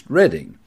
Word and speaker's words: Reading: Now, Reading, which is an interesting English word because it's the same spelling as Reading, but it's pronounced Reading.